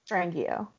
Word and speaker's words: Strangio: Strangio.